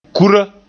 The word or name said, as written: kura